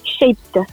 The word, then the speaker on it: Shaped: Déjà, est-ce que je le prononce bien ? – Chapda. – Voilà, Chapda, alors c'est S-H-A-P-E-D, ça veut dire quoi ?